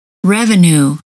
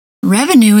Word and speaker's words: revenue: Revenue. Revenue is pronounced with the OO sound.